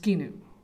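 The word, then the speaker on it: skinu (σκηνόω): The Greek word that's used in the text for lived is pronounced skinu.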